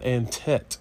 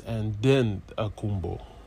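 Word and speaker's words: Antetokounmpo: Because it's not Antet, it's Antetokounmpos.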